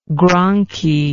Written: Gronchi

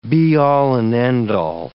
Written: be-all and end-all

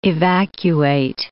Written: evacuate

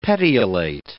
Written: petiolate

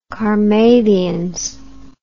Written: carmathians

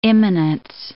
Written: immanence

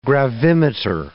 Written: gravimeter

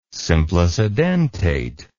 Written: simplicidentate

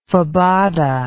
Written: fabada

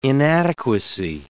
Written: inadequacy

